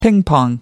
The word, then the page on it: ping pong: American English